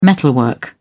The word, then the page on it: metalwork: British English